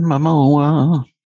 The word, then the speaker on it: Momoa: Always pronounce his last name wrong. Momoa, or how do you pronounce his last name? – Jason Momoa.